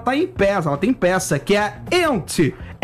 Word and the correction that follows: Yentl: Ente in Concert. – Yentl. – Eu vou fazer com pronúncia Yentl in Concert.